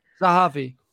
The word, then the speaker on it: Zahavi: Zahavi, is it? – Zahavi. – Yeah. – Aaron Zahavi.